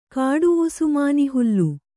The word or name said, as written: ಕಾಡುವುಸುಮಾನಿಹುಲ್ಲು